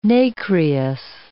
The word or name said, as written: nacreous